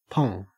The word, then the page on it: pong: Australian English